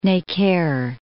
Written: Necker

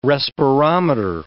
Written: respirometer

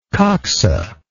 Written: coxa

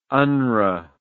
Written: unrwa

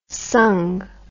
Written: sangh

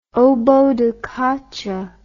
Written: oboe da caccia

